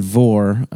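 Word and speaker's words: Mhòr: And then Vor, pronounced exactly how it's spelled. – Yep. – M-H-O-R. – That's it.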